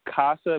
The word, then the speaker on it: Kassa: And it's Casa. Am I saying that right? – Yes, Casa. Yes, K-A-S-S-A. – Yes, ma'am.